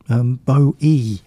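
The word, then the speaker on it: BOØWY: And in Japan, a massively popular solo singer and ex-frontman of the group Bowie, B-O-O-W-Y, pronounced B-O-H-Y.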